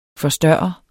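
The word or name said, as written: FORSTØRREDES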